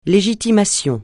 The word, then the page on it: légitimation: francais